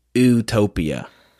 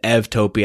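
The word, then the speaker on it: eutopia: Now, in Western's pronunciation, it would be like utopia. That's not good enough. But luckily for us in Greek, EU is pronounced ev. So I pronounce it evtopia, and I put an accent on the top of the U so that you can see that it's differently.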